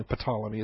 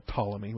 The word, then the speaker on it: Ptolemy: I apologize for calling Ptolemy. It's been my habit for decades now. Most folks call it Ptolemy with a silent P. Okay, I just do Ptolemy because I'm goofy.